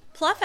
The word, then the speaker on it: Pluff: Well, Pluff Avenue is off of one of the main streets in the retail world. Would you spell that? – P-L-U-F-F. Pluff. Pluff Avenue. – Not P-L-O-U-G-H. – Nope. – Gotcha. – Pluff. P-L-U-F-F.